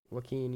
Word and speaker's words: Joaquin: Joaquin, yeah.